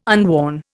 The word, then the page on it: unworn: Indian